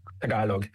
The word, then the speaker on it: Tagalog: How do you say it again? Tagalong? – Tagalog, yes. It's Taga.